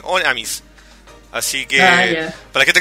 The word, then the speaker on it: Honnêamise: Y, para la gente que no lo sabe, se dice The Wings of Oneamis. La H no se pronuncia.